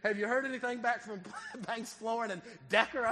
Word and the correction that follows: decor: And I didn't realize I had said Decker. It's decor.